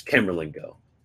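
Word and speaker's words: Camerlengo: Carlamingo? Like the cardinal dude who is Camerlingo. It's Camerlingo. Camerlingo.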